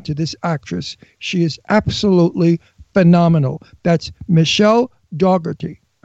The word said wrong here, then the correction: Dockery: How do you say it? – Dockery.